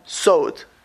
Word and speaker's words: Sod: But the Talmud calls Kabbalah Soid. It's the secrets. Sod. S-O-D is how you spell it in English. Or Samach Vov Dalid in Lashon HaKodesh.